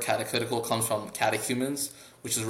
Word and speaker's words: catechetical: So in light of that, I noticed that you were working on a catechetical, I don't know if I'm pronouncing that right. That word is such a tongue twister. – Catechetical, yeah.